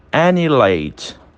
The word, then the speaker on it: annihilate: E por último, nós temos annihilate. Annihilate. Annihilate, aniquilar, né? Também não tem participação do H. Ele fica mudo entre duas vogais.